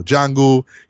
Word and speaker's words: Django: The P is silent, right? Similar to, you know, Django, you know, the D is silent, right?